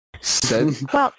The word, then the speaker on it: sedulous: But because it's English, we pronounce it like a J, because English is stupid. – Wait, what? – Sedulous. Sedulous. It sounds like a J. Sedulous. In English, we do that sometimes with D sounds.